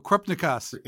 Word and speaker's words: Krupnikas: Oh, Krupnikas.